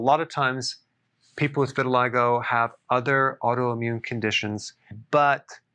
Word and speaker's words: vitiligo: I want to talk about vitiligo. Vitiligo. That's how it's pronounced.